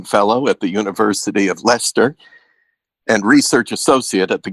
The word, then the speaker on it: Leicester: Did I pronounce Leicester right? it's Leicester. – Leicester.